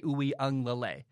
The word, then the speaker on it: Uiagalelei: I'm pretty sure you say it, DJ Uiagalelei.